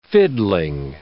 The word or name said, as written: fiddling